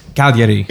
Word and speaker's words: Cagliari: Cagliari. What did I say? – It's a tough one. You said Calgary. Like it was like a weird Italian pronunciation of Calgary.